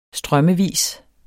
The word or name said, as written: strømmevis